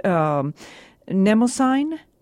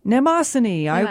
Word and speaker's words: Mnemosyne: Mnemosyne. – Mnemosyne.